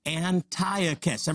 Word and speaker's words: Antiochus: You say it like this, Antiochus. Everybody say that, Antiochus.